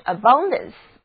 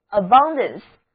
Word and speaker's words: abundance: 我们在它的前面加上 AB 这个表示强调的前缀，后面再跟一个名词后缀 ANCE, 得到的新单词就是 abundance, abundance. A-B-U-N-D-A-N-C-E, 字母 U 要读作 A, 第二个音节重读音节 Abundance, Abundance, 因为词根 U-N-Z 表示水或者波浪，那么想想看，微风吹来，湖面上就会泛起水波连连，这么多的水波很丰富，所以呢 Abundance 的意思就是丰富或者充裕，是一个名词。